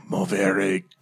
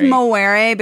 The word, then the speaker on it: movere: I think it's movere because I know that I spent a lot of my youth being corrected by people who pretentiously pointed out that V's were pronounced like W's.